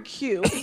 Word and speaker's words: queue: Okay, so the word Q, like you're standing in a queue, it is the letter followed by four silent letters.